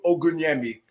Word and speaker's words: Ogunyemi: So my name is pronounced Olaolu Ogunyemi. And I'm glad we have it phonetically spelled there because, you know, like I said, some people, we tend to butcher it.